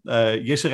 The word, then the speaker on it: Jisse: It's the same pronunciation since the moment I was born. Jisse Reitsma from the Netherlands.